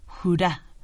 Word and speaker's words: hurra: Bueno, en realidad dijo jurá, porque los suecos ponen el acento en la A, pero quiere decir lo mismo.